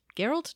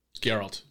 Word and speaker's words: Geralt: this is Geralt. Geralt? Gerald. – Geralt. – Geralt. I said it right the first time.